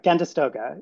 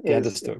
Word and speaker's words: Gandastoga: Um, uh, can you s- say a little bit about that, and am I pronouncing that correctly? – Sure, uh, Gandastoga. – Gandastoga. Is, I think right. Um, and and and this is based on textual sources, so uh, it's you know I'm not sure that I have the pronunciation exactly right either.